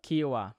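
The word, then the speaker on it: Kiawah: Kiawa. – Kiawa.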